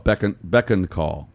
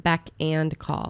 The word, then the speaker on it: beck and call: I've always heard it beck and call. – That's the correct way to say okay.